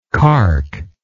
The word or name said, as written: cark